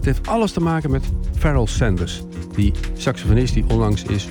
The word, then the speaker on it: Pharaoh Sanders: Je schrijft het Pharaoh A, maar je moet uitspreken Farrell Sanders.